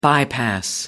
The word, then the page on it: bypass: American English